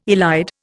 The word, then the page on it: elide: Indian